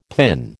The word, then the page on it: PIN: American English